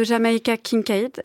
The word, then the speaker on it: Jamaica Kincaid: La voix de Jamaica Kincaid, je prononce bien son nom.